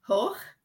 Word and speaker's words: Hoch: Hoch.